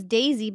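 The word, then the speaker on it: Deissy: It's D E I S S Y. Like you yeah. would pronounce it in Spanish. So,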